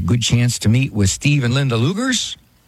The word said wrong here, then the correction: Lugers: Oh, Leaguers, that's the way to pronounce it. Sorry about that. Pronounced Leaguers.